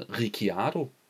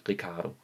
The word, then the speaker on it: Ricciardo: Jeg har indtil videre hele tiden kaldt ham Daniel Ricciardo. Det hedder han åbenbart ikke. Sådan udtales det åbenbart ikke, har jeg nu forstået. Det udtales åbenbart bare Daniel Ricciardo.